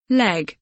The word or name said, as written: leg